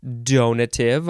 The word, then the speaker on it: donative: The next word is also donative or donative or donative. Donative or donative with a long A sound in the middle.